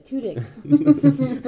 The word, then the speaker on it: Tudyk: That's how you say it, Tudyk. But I wrote it phonetically so I could write dick, you know.